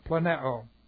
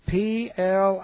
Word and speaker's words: planeo: Planeo. It's not an ordinary word in the language. Planeu, long O. Almost like Plano.